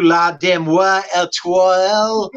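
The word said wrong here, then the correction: La Deuxième Étoile: This was one of our big winners on opening day. – Yeah, that's right. – Frank's call 8,000 times. La Deuxième Etoile.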